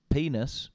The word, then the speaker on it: Peaness: They're called Penis, but not. I'll spell it. – Oh, I've seen their name. I've seen their name. – P E A N E S S.